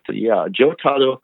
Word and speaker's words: Jotaro: Anyways, a couple more characters I just wanted to discuss is one character that I thought was interesting, that is the character, I'm going to probably pronounce it wrong, Jotaro. Am I anywhere Jotaro. – Close? – Yeah, that's Jotaro. Yeah, that's Usagi's son.